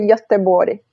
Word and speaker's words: Göteborg: Se for com, com sotaque sueco, é Göteborg.